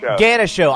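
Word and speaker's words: Ganucheau: So Sunday night, the IHL's candidate list got leaked to Ganuchu. Am I saying his name right? Ganuchu? – No, Ganisho.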